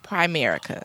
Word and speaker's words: Primerica: So, let me first correct you in the pronunciation of it. – Okay, so just Primerica.